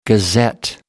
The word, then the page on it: gazette: American English